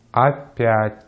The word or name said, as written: опять